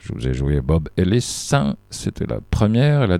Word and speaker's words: Ellis: Robert Ellis, Ellis c'est un prénom et un nom de famille courant au Pays de Galles. Il se prononce toujours Ellis, pas Elias, mais Ellis.